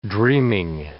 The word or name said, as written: dreaming